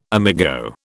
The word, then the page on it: amigo: British English